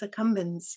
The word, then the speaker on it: accumbens: I'm not pronouncing this right. Nucleus accumbens power. – Yeah, the nucleus accumbens. Yeah. – Accumbens. Thank you.